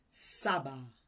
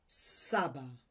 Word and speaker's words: saba: Seven is pronounced Saba. Saba.